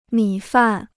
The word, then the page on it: 米饭: mǐ fàn